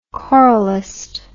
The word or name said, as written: choralist